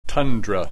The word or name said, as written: tundra